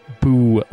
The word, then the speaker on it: boo: All right, you might as well explain the thing about boo in Latin. – It means I cry. Here. B-O-O, because O is first person singular. So it's pronounced bo-o, and it means I cry.